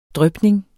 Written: drypning

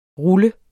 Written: ruller

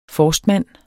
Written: forstmand

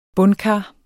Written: bundkar

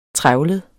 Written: trevlet

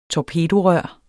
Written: torpedorør

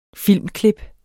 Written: filmklip

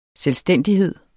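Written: SELVSTÆNDIGHED

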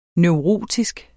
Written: neurotisk

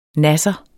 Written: NASSERNE

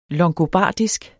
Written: longobardisk

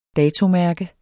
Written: dato

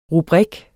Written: rubrik

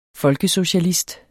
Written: FOLKESOCIALIST

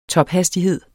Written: tophastighed

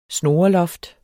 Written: snoreloft

